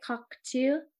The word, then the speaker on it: Cocteau: Um, Picasso painted her and Jean Cook cockatoo. Is that how you say it? Cockatoo? Cockatoo. Mm-hmm. – Anyways, everybody knows who he is. It's spelled C O C T E A U.